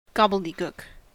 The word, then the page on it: gobbledygook: American English